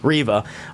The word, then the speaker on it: Reva: Reva! excuse me, I have to say it correctly. Reva.